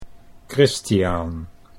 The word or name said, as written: Christiaan